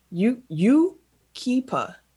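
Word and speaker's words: Yucaipa: Number seven, I had to look up how to pronounce this. U-Keepa. Yukiba, California.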